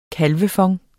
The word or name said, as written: kalvefond